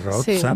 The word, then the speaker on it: Rozsa: sonora, amb una banda sonora fonamental a la història del, del cinema negre, obra de Miklos Rozsa, que m'ha dit un amic filòleg que es pronuncia Rozsa. – Sí, ho has dit molt bé, sí.